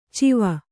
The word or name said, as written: ಚಿವ